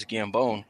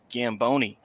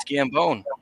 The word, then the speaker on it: Gambone: It saves me a lot of time when I use this name because my normal name is Gambone, and everybody loves to pronounce it Gamboni for some reason. So I always got to tell them, no, it's Gambone, like bones.